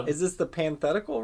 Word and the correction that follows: pataphysical: review, or... I'm saying it wrong. – No. If we were... I was going to say... There was a brief moment where... – Pataphysics. – If the pataphysical review...